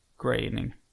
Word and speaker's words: Groening: Så då läste jag att det ska uttalas som färgen grå. Groening. – Groening menar du väl? – Nej. – Alltså grå. – Nej, det ska på grey på engelska.